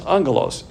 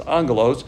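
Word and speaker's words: angelos (ἄγγελος): And that's angelos, A-G-G. When you see a double G, it's pronounced as an N. That's angelos.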